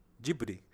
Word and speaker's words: Ghibli: Só que aí eu comentei com ele, o André Mizutani me comentou que no Japão, e o próprio galera, na verdade, fala Ghibli. Ghibli, que é a pronúncia, né? E, na verdade, o nome correto é realmente Ghibli, porque é uma palavra é, de origem italiana que significa vento quente do deserto.